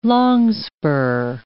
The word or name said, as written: longspur